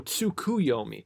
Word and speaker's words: Tsukuyomi: Tsukiyomi. Okay. Oh, Tsukuyomi. Tsukiyomi. I've been slightly mispronouncing it this whole time.